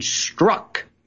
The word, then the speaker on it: Strzok: Now we find out this FBI agent struck. And by the way, if you can pronounce that, it's like a schnellen. There's one vowel. It's very S-T-R-Z-O-K. Struck, I believe, is pronounced correctly.